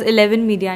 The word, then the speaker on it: Elleven Media: So eleven eleven is the name, but we pronounce it as Eleven Media and Talent.